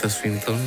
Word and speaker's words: Tilda Swinton: Tilda Swinton.